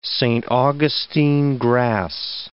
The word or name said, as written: Saint Augustine grass